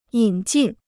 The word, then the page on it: 引进: yǐn jìn